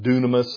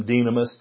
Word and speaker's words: dunamis: It's some pronounce it dunamis, some dynamis, but we get our word dynamite from that.